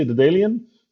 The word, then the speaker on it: Daedalean: Did I say it right? – Almost. I say Dedalion,